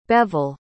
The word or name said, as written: Bevil